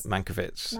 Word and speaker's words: Mankiewicz: Yes, Mankiewicz.